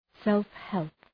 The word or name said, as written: self-help